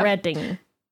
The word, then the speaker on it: Reading: which it's not called Reading as it's spelled. – No, definitely not. You've got to know Reading.